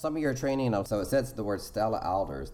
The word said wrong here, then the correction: Adler: Stella Adler.